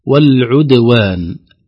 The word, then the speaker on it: والعدوان: والعدوان، البعض يقول والعدوان، والعد القلقلة تأخذ صفة الضمة في العين. نقول لا، انتبه، العين مضمومة فالشفتان مضمومة لضمة العين، أرجع الشفتين مرة ثانية عند النطق بقلقلة الدال حتى تخرج الدال خالصة من الضم مقلقلة ساكنة.